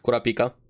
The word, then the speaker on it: Kurapika: Kurapika, whatever. – Kurapika.